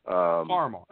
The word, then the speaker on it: Farmar: Farmar. – I don't know how it's pronounced. – Farmar.